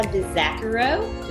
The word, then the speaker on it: Vizachero: Today, we have Olivia DeZaccaro. Did I say that correctly? Or close enough? – Perfectly.